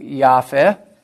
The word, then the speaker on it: Yahweh: And J stands for the divine name Yahweh. You might remember in German that J and Y, in German, J is pronounced Y. From that we get Yahweh.